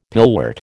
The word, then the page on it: pillwort: American English